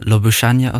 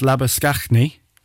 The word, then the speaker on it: Labuschagne: So, I've heard it pronounced Labuschagne, right? Which is like if the Saffas were doing it. But you've got your man who plays for Australia in the cricket, Marnus Labuschagne. That's the same one.